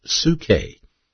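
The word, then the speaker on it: psuche (ψυχή): In the Greek New Testament, it's pronounced psuche, P-S-U-C-H-E, psuche.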